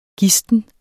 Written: GISTNE

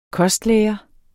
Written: kostlære

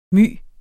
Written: my

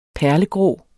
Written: perlegrå

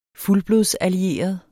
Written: fuldblods

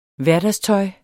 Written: hverdagstøj

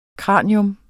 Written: kranium

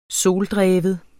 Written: soldreven

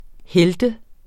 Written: helt

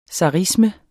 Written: zarisme

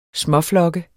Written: småflokke

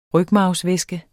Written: rygmarvsvæske

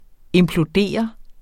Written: IMPLODERER

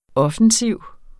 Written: offensiv

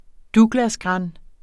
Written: douglasgran

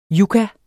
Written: yucca

